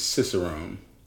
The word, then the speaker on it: cicerone: This week's word is Cicerone.